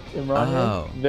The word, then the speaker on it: Imran Ye: Imran Ye, is that how you say it? – Yeah, it's like Kanye, but Imran Ye, very similar.